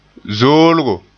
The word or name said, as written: zoolgo